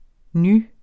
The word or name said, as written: ny